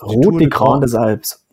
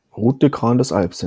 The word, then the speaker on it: Route des Grandes Alpes: Route des Grandes Alpes. – Grandes Alpes? – Ja. – Dein Ernst? – Route des Grandes Alpes, ja.